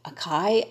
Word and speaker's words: acai: Like for the longest, I didn't know how to pronounce that word. I was like, A-C-A-I. Acai? I don't know. And I found out it's acai.